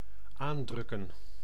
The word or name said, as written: tasser